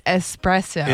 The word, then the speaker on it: espresso: I mean, if you sound it out, like the way that it's spelled, right, then it's espresso. – It's E-S-P-R-E-S-S-O.